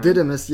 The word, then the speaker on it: Didymus: Didymus, yeah, Didymus.